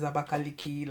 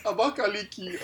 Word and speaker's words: Abakaliki: Other than the capital is Abakaliki, like.